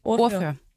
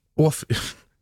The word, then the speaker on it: ordfører: Men flere politikere, blandt andre Trine Bramsen, det er Socialdemokratiets ligestillingsminister, Orfør. hun har udtalt, at, at hvad siger du? – Ordfører. – Ordfører.